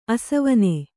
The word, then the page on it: ಆಸವನ: āsavana